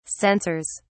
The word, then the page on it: sensors: British English